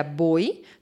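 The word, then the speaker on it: mboi: N-boi, que a gente pronuncia boi, significa cobra em tupi.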